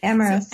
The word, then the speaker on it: Amyris: The other case takes us into Amyris. Is that, am I pronouncing that sort of right? – Amyris.